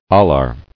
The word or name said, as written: Alar